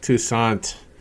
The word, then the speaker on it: Toussaint: My buddy Eovaldi starts against, is it Tukey? Toussaint, I believe. – Toussaint. The eye is silent.